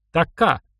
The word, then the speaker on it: Така: «Така», — сказал им Кузьмич, делая ударение на последний слог.